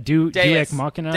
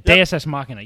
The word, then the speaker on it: deus ex machina: The tracking device in Iri's body was the most deus ex, how do you say that? – Deus ex machina. – Deus. That's it. Deus ex machina. Yeah, that's how you say it.